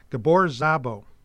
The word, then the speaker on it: Gabor Szabo: And the song's ending jam was based on the 1966 song Gypsy Queen, originally written and performed by an Hungarian guitarist, Gabor Szabo.